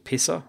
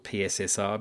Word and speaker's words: PSSR: He has an outfit out these days called Pisser. I call it PSSR because that's how it's spelt, but it's actually Pisser as Frank calls it through the conversation.